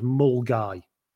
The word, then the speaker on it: Milngavie: This person, they have been in touch with me and said, I was playing in the Open at, now it's spelt Milngavie, but they've helpfully said it's pronounced Mulgai. – Okay, cool. – So we're playing in the Open at Mulgai